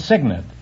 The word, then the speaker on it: cygnet: No, it's cygnet. It's spelled C-Y-G-N-E-T, but it's pronounced cygnet.